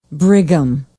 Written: brigham

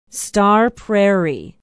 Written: star prairie

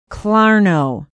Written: Clarno

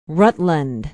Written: rutland

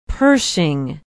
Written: pershing

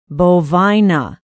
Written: bovina

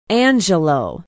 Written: angelo

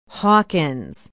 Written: hawkins

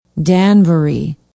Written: Danbury

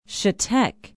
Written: chetek

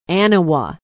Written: aniwa

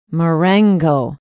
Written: marengo

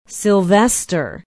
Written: sylvester

